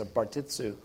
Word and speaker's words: Bartissu: And it's jiu-jitsu and the first syllable of his name, Barton, put together. So Bartissu.